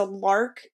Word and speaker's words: LARQ: But one that we wanted to recommend, and my husband and I actually have one, is a Lark self-cleaning water bottle. So it's spelled L-A-R-Q.